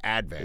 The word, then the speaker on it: Advance: because we put the accent on the first syllable on things in Staten Island. So not the Staten Island Advance, the Advance.